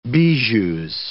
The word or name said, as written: bijoux